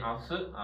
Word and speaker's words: 明日: 一般来说呢，明日这两个字念阿斯啊，一般念阿斯，它的训啊，念成阿西他的时候呢不写字，阿西他是不写字的，一般。